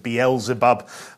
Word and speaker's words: Beelzebub: Baal Zebub, yeah, you may have heard, it's quite a well-known term, and some people pronounce it Beelzebub, which is Lord of the Flies.